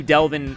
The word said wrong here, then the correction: Devlin: Hodges because his name is actually Devlin. Right? We both said that wrong, Connor.